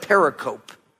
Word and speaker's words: pericope: It's spelled like pericope. I used to call it pericope, and then someone who was smart said, no, it's pericope.